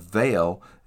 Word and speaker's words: Mhaol: They're called Vale, and that's spelled M H A O L because it's Gaelic.